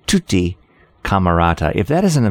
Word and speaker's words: Camarata: I'll spell that T-U-T-T-I, as you might think. And then Camerata, C-A-M-A-R-A-T-A, Camerata, who helped co-found Disneyland Records, known today as Walt Disney Records.